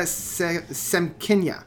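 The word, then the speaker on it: Semkina: And it has a performer on that called Mariana Sikkim. I can never pronounce her last name. It's Mariana I'm going to actually look it up so I can... Mariana Semkinya. And the two sound eerily familiar, whereas I found like Yvette Young was much more of a complex jazz math.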